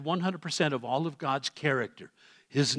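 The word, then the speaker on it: character (χαρακτήρ): That word in the Greek, the exact representation, the Greek word is the word character. That's exactly how it's pronounced in Greek.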